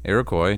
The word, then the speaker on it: Iroquois: Iroquois chief, and 90 members of his tribe.